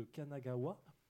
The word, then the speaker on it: Kanagawa: On passera ensuite sur une chronique jeu de plateau avec Christophe qui va nous parler de Kanagawa.